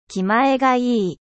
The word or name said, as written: genereux